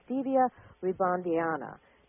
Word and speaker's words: Stevia rebaudiana: It's called Stevia Rebaudiana. Now, correct me if I did not pronounce that correctly. – Perfect.